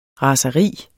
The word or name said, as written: raseri